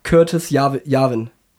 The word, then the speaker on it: Curtis Yarvin: Und er nennt unter anderem auch den Namen Curtis Yarvin. Richtig ausgesprochen? Oder? – Ja, das stimmt.